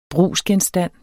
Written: brugsgenstand

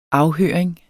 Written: afhøring

